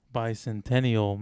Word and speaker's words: Bicentennial: Bicentinel. Bicentennial, man. – Oh, yeah, Bicentennial.